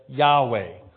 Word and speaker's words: Yahweh: In Hebrew, it is spelled YHWH and pronounced as individual letters. Yod, He, Wah, He. Later, translators added vowels and changed some of the letters to pronounce it as Yahweh or as Jehovah.